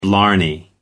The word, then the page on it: blarney: American English